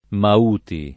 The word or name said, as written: Mauti